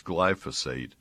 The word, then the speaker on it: glyphosate: It looks like glyphosate, but there's a syllable that is not there. It's glyphosate. And it's been around 40 years.